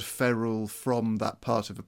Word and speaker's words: ferrule: It is ferrule. It is ferrule. Which is F-E-R-R-U-L-E, in case my pronunciation wasn't getting that right.